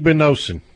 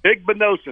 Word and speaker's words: Igbenosen: But, you know, some of those freshmen I thought did a good job having just come in, stepped on a college campus, especially I think it's Isabosen, however you pronounce it. – Igbenosen. – Igbenosen, there we go.